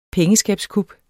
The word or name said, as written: pengeskabskup